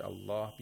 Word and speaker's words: الله: And the lam is pronounced with taghlil, with thickness, because it's preceded by fatha. Lam is only pronounced with taghlil in the name of Allah.